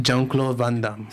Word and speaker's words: Jean-Claude Van Damme: Jean-Claude Van Damme? – Jean-Claude Van Damme.